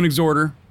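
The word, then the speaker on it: Exhorder: I'm going Exhorter. – Yeah, that's what it is. – All right. – The H is silent. Matter of fact, we were thinking about making T-shirts that said Exhorter. The H is silent, motherfucker. So, yeah, but that's how you pronounce it. It's Exhorter.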